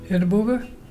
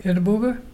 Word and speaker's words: irrabuga: Edinburgh. Edinburgh is a dolphin. You spell it IRRABUGA. Can you say Edinburgh?